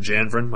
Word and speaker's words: Janvrin: You can find me on Twitter at Richard Janvarin. My last name is spelled J-A-N-V-R-I-N.